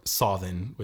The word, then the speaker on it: Samhain: 2/3 of their milk, and then specifically to be given to them on Samhain, or Sothin would be the more old Irish pronunciation, which still being Halloween.